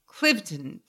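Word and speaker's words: Cliveden: Clivedon. Please no N. No third syllable. Clivedon. And left with, you know, everybody involved.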